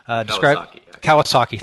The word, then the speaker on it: Kawasaki: But one of them was, as a product manager, you'll have little to no actual authority, and you're quoting Guy Kawasaki. I think that's how you pronounce his name. – Kawasaki, thank you.